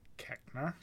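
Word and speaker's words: Koechner: David Kolchner, he's Uncle Howie. – Keckner? – Is that how you say it? – Yeah.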